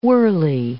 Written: whirly

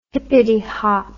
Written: hippity hop

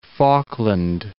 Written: Falkland Islands

